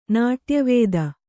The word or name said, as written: ನಾಟ್ಯವಿದೆ